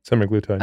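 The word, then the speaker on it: semaglutide: How do you say it? Semi-glutide. Okay, yeah. play into this insulin resistance conversation if it does.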